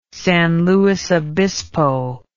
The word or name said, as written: San Luis Obispo